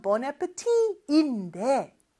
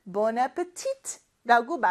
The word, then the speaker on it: Bon appetit: Bon appetit인데 발음이 마지막 T를 프랑스어는 발음하지 않거든요. 근데 미국에서는 Bon appetit라고 마지막 T까지 발음하는 경우도 있습니다. 그거는 발음 참고해 주시면 돼요.